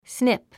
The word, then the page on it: snip: sníp; スニップ